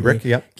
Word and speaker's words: Kubrick: Kubrick. Yep.